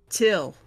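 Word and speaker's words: Thgil: It's spelled like light, but backwards, but it's pronounced Till.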